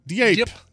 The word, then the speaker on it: Diep: Diep. Dip. – Diep. – Lan Diep. – D-I-E-P.